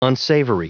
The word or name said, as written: unsavory